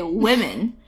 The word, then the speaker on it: women: But when you say women, because your mind knows there's an E coming, it kind of thinks in advance and it makes the O a little more E sounding.